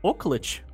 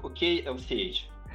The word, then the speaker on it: OKLCH: Она читается как Оклэч. Прости, господи. Скорее как OKLCH.